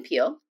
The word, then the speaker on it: Peel: One, they can Google me, Shannon Peel, and my last name is spelled P-E-E-L.